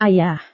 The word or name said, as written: ayah